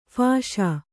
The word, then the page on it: ಫಾಸಿ: phāsi